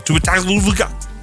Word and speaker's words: Tua Tagovailoa: Oh, Tua Tagovailoa.